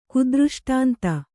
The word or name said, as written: ಕುದೃಷ್ಟಾಂತ